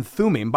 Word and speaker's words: Thummim: So to go back to your question, the Urim and Thummim, by the way, great job in pronouncing those.